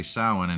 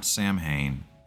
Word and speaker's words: Samhain: So basically Samhain, and it's pronounced like S-O-W and then W-I-N, not Samhain, irritates the hell out of me when people say that.